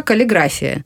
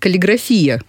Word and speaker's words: каллиграфия: А вообще, как правильно все-таки? Вообще, по нормам русского языка, каллиграфия. Но в среде дизайнеров, насколько я знаю, частенько употребляется каллиграфия почему-то. – Здесь ударение не, я думаю, не столь важно.